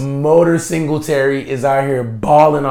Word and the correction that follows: Moda: Sir, it's Moda. – Moda? I'm sorry. Moda Singletary is out here balling. – Say it like you drink iced tea from a straw.